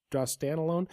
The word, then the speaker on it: Drostanolone: Ben Rothwell with testosterone in 2013, Brian Ortega for Drostanolone." Did I say that right? Did I nail that one? – Close enough. – Drostanolone.